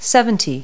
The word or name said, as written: seventy